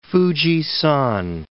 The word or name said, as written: Fuji-san